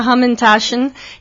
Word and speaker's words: Hamantaschen: Pues mira, se llama Hamantaschen, que en alemán significa bolsillos de jamón, ¿no?